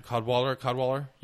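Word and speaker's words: Cadwalladr: Kanderwaller, right? – I said Cadwaller. – Cadwaller. Yeah, Cadwaller. It's spelled weird. It's like there's a W-A-L-L-A-D-R at the end.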